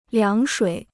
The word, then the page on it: 凉水: liáng shuǐ